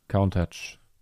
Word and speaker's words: Countach: Countach. – Also, Kuhntag. Also, ich habe mich von Lambo belehren lassen und der ist ja großer Autofan, wie wir wissen. – Ja. – Und der hat gesagt, Kuhntag.